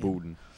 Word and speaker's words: Budden: Yeah, it's like, and like I said, you know, we had a little discussion beforehand, and I said that Joe Budden. Or Joe Budden, I don't know how to say his fucking name. – Budden, Joe Budden.